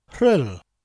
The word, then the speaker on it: Rhyl: Rhyl is spelt R-H-Y-L and probably looks odd to non-Welsh speakers.